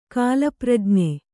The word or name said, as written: ಕಾಲಪ್ರಜ್ಞೆ